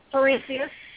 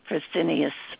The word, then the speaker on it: Fresenius: Fresenius. – Fresenius? – Mm-hmm. Fresenius. – Oh, that's how you pronounce it, Fresenius.